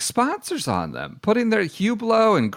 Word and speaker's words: Hublot: He's got Hublot, which I have to admit, my pronunciation adventures, I would absolutely call Hublot if I had not listened to, you know, heard it referenced in several rap songs and things like that. It's Hublot.